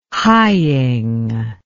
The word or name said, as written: hying